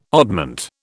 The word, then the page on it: oddment: British English